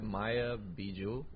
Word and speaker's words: Maya Bijou: Like, it's her name is Maya Bijou, something like that. Maya Bijou. – Yeah, that's how you say it.